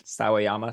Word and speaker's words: Sawayama: I would also like to say that I'm not sure if I pronounced Rina Sawayama's name correctly beforehand, but it is pronounced Sawayama.